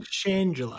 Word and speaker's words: Shangela: And yeah, in real life, she's played by well, her name that she goes by is Shangela. I think that's how you say it. Shangela. – I think Shangela.